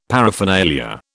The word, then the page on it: paraphernalia: British English